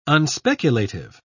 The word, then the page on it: unspeculative: British English